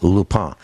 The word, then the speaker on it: Lupin: Lupin, as it's pronounced in French, spelled Lupin, L-U-P-I-N.